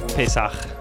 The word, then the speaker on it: Pesach: Pesach.